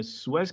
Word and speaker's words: Suez: I think we all have heard at this point there's an interesting thing going on with the cargo ship in the Suez Canal. Am I pronouncing that correctly? Yeah. Okay. I'm getting nods.